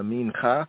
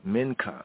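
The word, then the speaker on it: minka (מנחה): Others say the minka, minka. My here I and I say the minka, as it's pronounced in modern Hebrew, minka.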